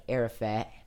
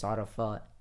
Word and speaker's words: Arafat: Or the Americans would say like Arafat, but it's Arafat.